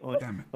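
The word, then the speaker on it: Oneiros: I always pronounce it as like onerous. – Oneirus? I always said new. – I think Reader sent me a link to how to pronounce it, and I heard it, and then I forgot. I think it was like Oneirus.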